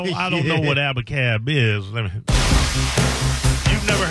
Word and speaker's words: Abacab: Abacab.